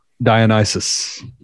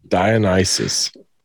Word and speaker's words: Dionysus: Dionysus. – Dionysus. – Yep. Dionysus. – It sounds Dionysus. Like the devil.